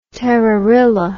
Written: terrorilla

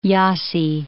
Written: Jassy

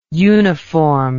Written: uniformed

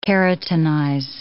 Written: keratinize

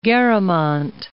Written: Garamont

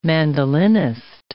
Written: mandolinist

